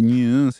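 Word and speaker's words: Neue: suis assez impressionné, hein, vous l'avez compris, hein, parce que ce single de New Graphic Ensemble, si je fais nu, c'est, j'y peux rien, N-E-U-E-U, c'est comme ça que ça s'écrit, nu,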